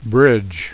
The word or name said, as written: bridge